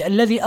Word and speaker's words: الذي: كلمة الذي، هذه الكلمة لابد من مراعاة فيها حرف الذال، هذه الذال تنطق ذال هكذا ولا تنطق زايا، لا تقل الذي، لا تقرأها الذي هم فيه، بل تقرأ هكذا الذي آل.